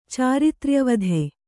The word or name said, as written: ಚಾರಿತ್ರ್ಯವಧೆ